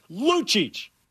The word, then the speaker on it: Lucic: It's pronounced Luchich, fool.